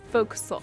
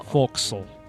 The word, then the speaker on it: forecastle: It's spelled forecastle, but it is pronounced Foc'sle. – Foc'sle.